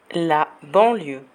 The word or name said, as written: la banlieue